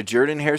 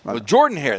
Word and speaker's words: Jordan-Hare: First of all, I pronounced it wrong back then. – Well, Jordan-Hare. They said, no, if you're going to cover the team, you got to say it right.